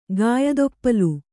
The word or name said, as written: ಗಾಯದೊಪ್ಪಲು